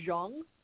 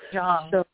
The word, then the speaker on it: Zhang: And I believe I attended that once when Grandmaster Yunming Zhang, am I saying his name correctly, was in town? – Yes, Grandmaster Zhang. ZH is sort of has a J sound to it in Mandarin. – Zhang.